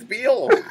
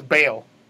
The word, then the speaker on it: Beale: No, it's Beale. Beale. It's a long E sound. Beale. Like a seal? – I thought that was Bale. – No, Bale would be B-A-L-E or B-A-I-L.